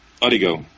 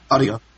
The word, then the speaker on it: Odigo: How do you pronounce it? Odigio? – Odigo, yeah. – Odigo, okay.